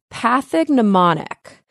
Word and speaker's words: pathognomonic: And the word is pathognomonic. So it's spelled P-A-T-H-O-G-N-O-M-O-N-I-C, pathognomonic.